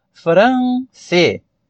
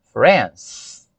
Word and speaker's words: France: Agora, referente à palavra, ou melhor, ao país France, é interessante nós destacarmos que a palavra France, ela é monossílaba. Ela só tem uma sílaba. Apesar de você olhar e, como brasileiro, falante do português, pensar France. Não. É uma pronúncia só. France. Talvez na escrita dê essa ideia pra gente de dissílaba.